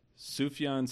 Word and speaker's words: Sufjan: Serena Williams, Terry Crews, Charles Manson, Sufjan Stevens.